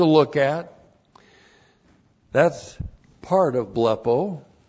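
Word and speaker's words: blepo (βλέπω): And then we have blepo. Blepo, B-L-E-P, long O, blepo.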